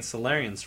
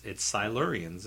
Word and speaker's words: Silurians: We were saying Solarians for so long, and it's Silurians, you know.